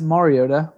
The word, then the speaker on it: Mariota: Yeah, it's pronounced Mariota, but you can call him whatever you want.